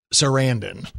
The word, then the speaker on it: Sarandon: And it rhymes with Brandon. It's Sarandon.